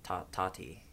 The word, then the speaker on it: Tati: I couldn't make it through Tati's first, if I say it fast, Tati, am I still saying it right? – Tati Westbrook. – Tati. – Tati. – Tati, I need to emphasize the second T.